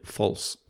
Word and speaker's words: Pfalz: How the hell do you pronounce P-F-A-L-Z? – False. – You just I would think it's false. – Yeah. – Silent P.